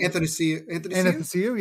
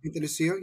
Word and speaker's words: Anastasiou: Anastasiou, yeah. – Anastasiou, yep.